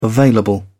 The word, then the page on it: available: British English